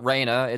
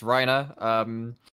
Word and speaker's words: Reiner: It's just like, okay, like, guys, his name is not pronounced in English, it's pronounced in the Japanese pronunciation of those letters, because he's a Japanese guy, so it's not Reiner, it's Reiner.